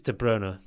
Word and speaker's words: De Bruyne: So it's De Bruyne.